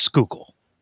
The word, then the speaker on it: Schuylkill: schuylkill it's okay. schuylkill river Skookle.